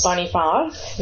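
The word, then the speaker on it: Boniface: uh, University de Saint Bonfons. – Bonifance. There you go.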